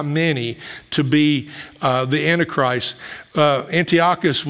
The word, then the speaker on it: Antiochus: This is Antiochus. That's the way I pronounce his name.